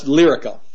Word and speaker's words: Lyrica: The blockbuster drug Lyrica, I guess that's how you pronounce it. Lyrica. – That's Lyrica. – Lyrica. There we go.